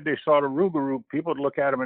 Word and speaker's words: Rougarou: A Rougarou.